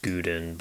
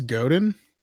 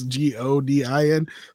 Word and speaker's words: Godin: I've always pronounced it Godin, but I know that's wrong. – That's 100% wrong. I believe it's Godin. – Probably. – Because there's no U.